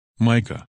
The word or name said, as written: Micah